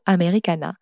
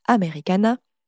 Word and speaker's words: Americana: Elle s'esclaffe faire en entendant le mot « Americana » prononcé avec jubilation, entraînant sur la quatrième syllabe,